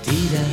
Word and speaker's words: ti: La mia vita tira. ¿Te quiero? Maldita sea. Es muy fácil. Te amo, ¿no? – Te amo. – Ti, ti, ti. – Te amo. – Ti, como si tiras así.